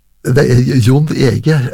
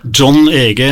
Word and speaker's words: John Ege: Jon Ege, eller var det John Ege det skulle være? – John Ege, i hvert fall kjenner jeg han som, ja. – Ja, det kan like godt uttale det riktig.